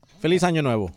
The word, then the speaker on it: Feliz Año Nuevo: Feliz Año Nuevo. Did I say that right? – Feliz Año Nuevo.